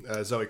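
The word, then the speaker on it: Zoë: And I think Chloe, right? Am I going to say that right? – Zoe Kravitz. – Zoe.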